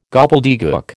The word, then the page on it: gobbledegook: American English